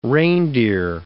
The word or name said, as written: reindeer